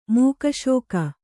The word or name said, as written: ಮೋಕ್ಷಕ